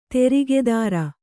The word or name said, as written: ತೇರ್ಗುದುರೆ